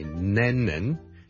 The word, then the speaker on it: Lennon: 第一個字呢就是人名來的， John Lennon。 John Lennon， John Lennon 一個英國的很出名的歌手作曲家 Lennon， L-E-N-N-O-N。第一個是 L 音，所以不要將它讀成 N 音，不是 Lennon，是 Lennon， Len 後面那個才是 N 音，所以 Lennon 就要兩個 N 音， Lennon 第一個 L， Lennon，所以 John Lennon 大家練一練。